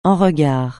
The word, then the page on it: en regard: français